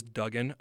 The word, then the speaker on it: Duggan: It is Duggan. I have been saying Dugan all year. It is Max Duggan, so I apologize.